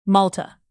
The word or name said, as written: malta